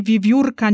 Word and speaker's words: wiewiórka: Prasłowiańskie e miało właśnie właściwości zmiękczające, o czym mówiłam, Stąd w wymowie wiewiórka nie mamy w, tylko w, miękkie w.